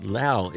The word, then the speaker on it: Laos: I've heard many people call it Laos. That is not correct. Laos is the correct pronunciation.